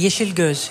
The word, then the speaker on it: Yesilgöz: Uh, spreek ik, hoe spreek je het nou precies uit? Yeshil Guls? Guls? Ik wilde al net inbreken. Yeshil Guls. Je zegt het eigenlijk wel goed.